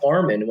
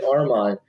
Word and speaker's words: Armand: And he changed his name just kind of like he was as tired as getting it pronounced Armin when it's really Armand. So before he passed away, at some point he changed his name to A-R-M-E-N.